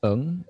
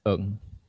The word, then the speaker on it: Ng: So my last name is Ng. It's spelled NG, but it's pronounced Ng. – Oh, okay. – Yeah, which is a weird one because people, I think most people don't really know how to pronounce it. It's like what's the vowel in it?